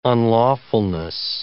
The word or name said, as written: unlawfulness